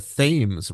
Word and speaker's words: Thames: Well, and of course, Connecticut, we have New London, Connecticut, with what is called there the Thames River, spelled the same as Thames, and nearby is Stratford.